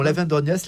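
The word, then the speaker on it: l'Aven d'Orgnac: On continue nos pérégrinations souterraines à Lavendorniac, c'est comme ça qu'on prononce ? – Absolument, Lavendorniac, là on est dans le sud du département,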